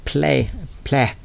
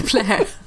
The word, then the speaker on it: Plait: Men jag tänkte börja faktiskt med en bok som jag har här framför mig, som är skriven av Philip Plait. Alltså P-L-A-I-T. Plait. – Doktor, det är nog så han uttalar det. – Plä, plä.